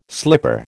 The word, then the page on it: slipper: American English